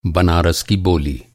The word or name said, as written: बोली